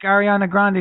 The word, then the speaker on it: Ariana Grande: Like if they knew, like if you said like Ariana Grande is going to be, am I saying that right?